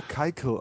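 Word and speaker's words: Keuchel: Warum der Keikel ausgesprochen wird, das erschließt sich mir auch ja, nicht.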